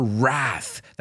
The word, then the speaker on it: wrath: Her wrath. That's a word with a silent W at the beginning.